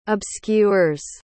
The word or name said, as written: obscures